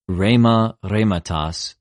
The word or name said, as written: ῥημα, ῥηματος